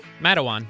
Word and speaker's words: Matawan: Matawan.